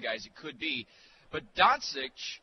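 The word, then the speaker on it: Dončić: And I'm not sure I'm pronouncing that name right. Doncic or Doncic? – Doncic. – Doncic, okay. – Yes.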